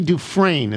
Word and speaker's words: Dufresne: Have you considered the Andy Dufresne, is that how it's pronounced? – Yes.